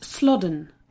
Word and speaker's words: Slaughden: "Old Slaughton." Joanne ducked her head back inside the car. "It's pronounced Slodden. I asked the woman at the hotel."